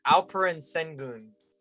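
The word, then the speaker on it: Alperen Şengün: It's Alperen Sengun.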